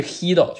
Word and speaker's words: Guido: 啊，好，大家好，然后我现在是斯坦福统计系第四年的 PhD，然后我的导师就是我有两个导师，我其中一个导师就是今年获得诺贝尔经济学奖的呃 Hido Imbens，然后这个人我先纠正一下刚才啊主持人的发音，就是他是荷兰人，所以说他这个 G 不发出这个 g 的音，他是 Hido Imbens，对，这个是很多人会念错的，比如说很多美国人会管会管他念 Guido。啊，或者叫 Guido 啊，但它实际是 Hido，就是发呵的音，其实 Hido Imbens。